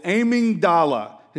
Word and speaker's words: amygdala: There is a little almond-shaped part of your brain called the, I hope I'm saying this right, called the amygdala. It's A-M-Y-G-D-A-L-A. Amygdala. The amygdala in your brain, how do you say it? Thank you. Amygdala. Amygdala. Yeah, thank you. It's not the amygdala.